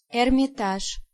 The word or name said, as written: Hermitage